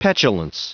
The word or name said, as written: petulance